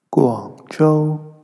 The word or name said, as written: Guangzhou